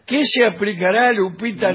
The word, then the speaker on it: Lupita: ¿Cómo se dice? ¿Lupita? – Lupita, sí.